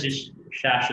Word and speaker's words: Shash: Did I pronounce your name correctly? I didn't go over it before the show and now I'm regretting it. Is it correct? – Shash is fine.